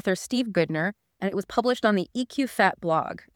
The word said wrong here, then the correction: EQ Fit: And that's pronounced EQ Fit, by the way.